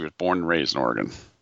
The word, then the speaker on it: Oregon: It's Oregon, by the way. You got to say it correctly.